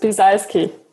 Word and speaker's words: Biesalski: It's pronounced Biesalski.